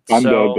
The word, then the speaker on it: Bundaberg: Bundaberg.